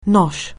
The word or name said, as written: nosh